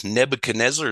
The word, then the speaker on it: Nebuchadnezzar: Well, Nebuchadnezzar is a fun one.